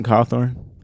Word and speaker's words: Cawthorn: Who is Madison Cartwall? Did I say that right? Who is this guy? – Madison Cawthorn. – Cawthorn, is that how you say it? – Yeah. Yeah, Madison Cawthorn.